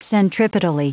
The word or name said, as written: centripetally